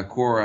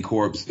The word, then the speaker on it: Corps: And by core, I mean like C-O-R-P-S, like the Marine Corps, Peace Corps. You might spell it corpse, but it ain't corpse.